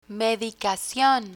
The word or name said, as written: medicacion